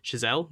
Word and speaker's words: Chazelle: Chazelle. That's how it's pronounced, I believe. – Chazelle.